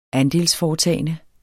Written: andelsforetagende

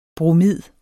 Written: bromid

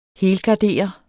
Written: helgardere

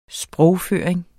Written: sprogføring

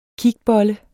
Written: kigbolle

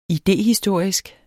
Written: idéhistorisk

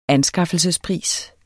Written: ANSKAFFELSESPRIS

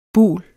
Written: bul

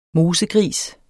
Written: mosegris

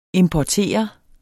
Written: importere

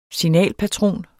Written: signalpatron

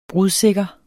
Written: brudsikker

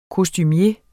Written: kostumier

